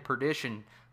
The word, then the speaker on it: Perdition: I don't know why I keep saying partition, and I'm like, partition? – Right, awesome. You're like, why did I say that?